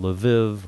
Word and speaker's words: Lviv: You recently came back from an extraordinary trip to Eastern Europe, and we started talking about Lviv, or Lvov, depending on how you pronounce it,